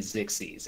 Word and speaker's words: Xyz: It's canonically Zixies is how you pronounce it, Mian.